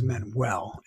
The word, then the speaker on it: Manuel: So my first name is Dai, D-A-I. Last name is Manuel, M-A-N-U-E-L.